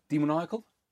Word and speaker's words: Demoniacal: Demoniacal? – Demonical. – There's an A in it though. Demoniacal.